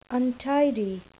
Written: untidy

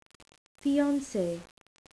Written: fiance

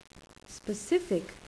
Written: specific

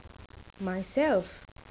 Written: myself

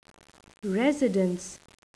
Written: residence

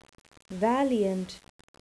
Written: valiant

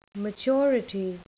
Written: maturity

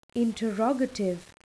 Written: interrogative

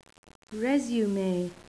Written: resume

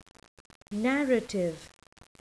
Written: narrative

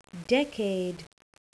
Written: decade

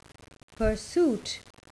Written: pursuit

